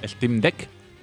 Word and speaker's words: Steam Deck: Steam Deck.